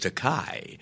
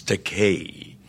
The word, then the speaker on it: Takei: He corrected the pronunciation of his name, but he then called me George Takei, a common mispronunciation of my surname because of the EI. They want to give it that Germanic Einstein, you know, pronunciation, mm-hmm. but it's the vowels in Japanese are pronounced like the Mediterranean languages, Italian, Spanish. It's Takei, E-I. However, there is a Japanese word pronounced Takai with an A, T-A-K-A-I, and that translates into English as expensive.